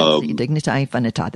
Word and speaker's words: Dignitas Infinita: Dignitas Infinita. I probably am saying that wrong. – No, I think that's Dignitas Infinita. That's what I would say too, yeah.